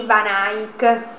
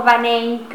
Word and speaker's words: Van Eyck: E poi sicuramente tra le cose degne di grande attenzione c'è il codice miniato dei due fratelli Van Eyck o Van Eyck, a seconda della pronuncia, alla fiamminga.